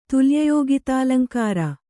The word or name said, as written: ತುಲ್ಯಯೋಗಿತಾಲಂಕಾರ